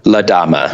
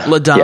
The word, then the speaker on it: La Dama: Is it La Dama or La Dama? – La Dama. – La Dama. – Yeah, like emphasis on the da.